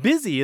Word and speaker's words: busy: B-U-S-Y. – Exactly. – So it has a pronunciation that doesn't seem to match its spelling. It's because the spelling and the pronunciation come from two different historical paths of English.